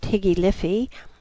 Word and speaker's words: High Life: Long-legged mulatto girls pranced across the street, chased by lithe and smirking bucks, while in the boites, the nightclubs, such as the High Life, pronounced Higgy Liffy, the elite sported Parisian gowns and broad-brimmed hats, while sipping cafezinos and French liqueurs to further ruin the balance of payments.